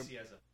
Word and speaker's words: Nicieza: Do you know how to Nicieza? – Nicieza? – Okay.